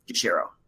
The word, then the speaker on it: Jushiro: By the way, Sir, sushi is my favorite food as well, and it also rhymes with my name, Jushiro.